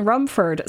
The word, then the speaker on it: Rumford: And Captain Rumford, see, I keep wanting to say it as whatever player character, or not player character, NPC it is, that pronounces it the way that sounds like there's an S has stuck in my head, and perhaps yours as well. But it's not. It's Rumford. So Captain Rumford says to the player character, I hear you are going to the fields.